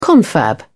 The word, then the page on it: confab: British English